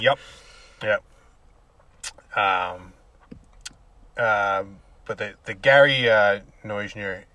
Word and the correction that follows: Noesner: Nesner. – I don't know why his name is spelled with a. – It's Noesner. – But it sounds like an E after the N when you say Nesner.